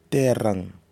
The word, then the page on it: terang: Nafe